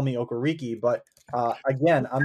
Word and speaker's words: Okereke: And I think it's Okereke. – Okereke.